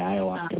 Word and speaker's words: ayahuasca: and you're trying to figure out how to pronounce ayahuasca. – Yeah, ayahuasca, that's it.